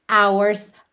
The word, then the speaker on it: hours: Então, 8 hours, hours começa com H, não é vogal, mas esse H não é pronunciado.